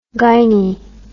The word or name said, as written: gyne